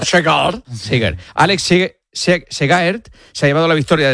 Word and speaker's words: Segaert: Y en ciclismo, el belga Alex Segaert, ¿lo he dicho bien? – Segaert. – Alex Segaert se ha... ¿Cómo? – Parecido. – Lo he dicho parecido, sí.